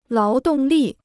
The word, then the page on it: 劳动力: láo dòng lì